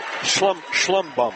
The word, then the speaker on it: Schlumbohm: S-C-H-L-U-M-B-O-H-M. But the accent's on the first syllable. It's Schlumbum.